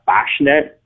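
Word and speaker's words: Kwaśny: So His name is Martian Fashnet, but it's spelled Kwasny, as all those Polish names are.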